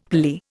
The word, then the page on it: phalli: Indian